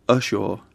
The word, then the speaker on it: Ushaw: I don't know if I'm going to pronounce it right. Maybe you can pronounce it for me. It's called Ushaw? – Ushaw. – Ushaw. – Ushaw College.